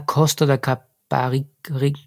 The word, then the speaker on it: Costa da Caparica: Ah, Costa da Caparica.